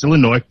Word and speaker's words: Illinois: For those who think that Illinois is the correct pronunciation, it's not. It's Illinois.